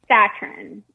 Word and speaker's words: Satran: Is it Satran or Satran? How do you pronounce your last name? – Satran. Yeah. – Okay, because I want to say it like the word Zatarans, the famous New Orleans rice.